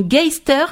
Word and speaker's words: Geister: Alors, vous dites qu'il y a une connexion qui semble bien étymologique entre le mot Yetzer et le mot allemand Geister, alors je ne sais pas prononcer Geister.